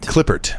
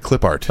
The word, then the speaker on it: Klippert: Klippert. – How do you spell it? – K-L-I-P-P-E-R-T, I think. – Okay, that's correct. That sounds correct. You know what my initial thought was? C-L-I-P-P-A-R-T. – Right, Klippert.